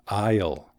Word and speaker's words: aisle: Cecilia, hay una palabra en inglés que rima con I'll. ¿Sabes qué es? – Um, I'll? es decir, pasillo? – Correcto. Pasillo en inglés es aisle. Se escribe A I S L E. Aisle. Suena exactamente como la contracción de I will.